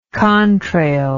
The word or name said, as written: contrail